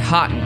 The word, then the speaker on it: KOTN: This is called Cotton, but it's spelled K-O-T-N. Cotton.